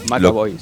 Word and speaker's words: McAvoy: Eh, James McCoy. Ah, acá me lo está mandando. Ahí va, bien, bien, bien. – McAvoy se pronuncia.